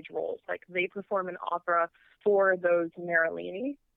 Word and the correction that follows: Merola: Um, it's so pronounced that, Merola. Merola, yeah. Oh, I totally yeah.